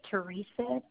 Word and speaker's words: Teresa: My first name is Teresa, T-E-R-E-S-A.